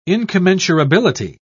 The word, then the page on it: incommensurability: British English